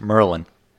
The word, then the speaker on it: Maryland: Maryland. It's pronounced Merlin.